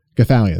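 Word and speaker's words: Gothalion: Gathalion, that's how you say his name.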